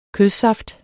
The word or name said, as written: kødsaft